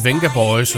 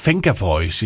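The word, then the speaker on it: Vengaboys: fordi at i Holland, der siger man Venga Boys i stedet for, fordi ved, det udtales som et F.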